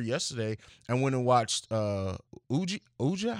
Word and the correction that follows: Ouija: How do you pronounce? Oh, Ouija. Ouija, uh, like, and because uh, I never, because the first one sucked, and I never went to see the sequel.